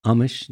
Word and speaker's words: Amish: But so you grew up in an Amish community. Is that how it's pronounced? – Amish, yes. – Amish, yeah. – Oh, is it Amish? – There's no H. I was wondering if it's Amish.